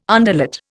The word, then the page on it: underlet: Indian